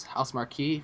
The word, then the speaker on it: Housemarque: It was published by Ubisoft, and developer was Housemark, or Housemarque. I believe it's Housemarque, if you pronounce it correctly.